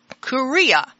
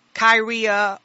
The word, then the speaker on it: Kuria: And it's called Kuria. And it's spelled K-U-R-I-A, and you can find this in Strong's Greek Concordance, and that would be 2959. And it's also, you will hear it as Kyria or Syria, and Kyria be K-Y-R-I-A, or Syria, which would be C-Y-R-I-A.